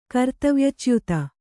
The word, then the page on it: ಕರ್ತವ್ಯಚ್ಯುತಿ: kartavyacyuti